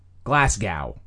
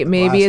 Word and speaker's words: Glasgow: Glasgow. You're not supposed to pronounce the W at the end, though. Apparently, it's Glasgow. – Okay, is actually how it's pronounced. – Oh. – Glasgow, not Glasgow.